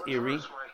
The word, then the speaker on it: Irin: But it's from the Hebrew, Irin, which is pronounced Irin.